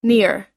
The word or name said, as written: near